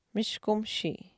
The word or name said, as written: mishkumishii